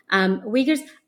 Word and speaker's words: Uyghur: Yeah, I'm really interested in your methodology because there's, you occasionally talk about ethnographic research, having interviewed Uyghurs. I'm pronouncing it as Uyghurs. How do you pronounce it? – Uyghur. – Uyghur.